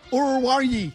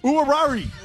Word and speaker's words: Oruwariye: O-R-U-W-A-R-I-Y-E. – Orurari. – Orurari.